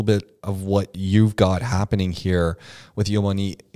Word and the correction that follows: Yamino: Did I say it right? – Yamino. – With Yamino.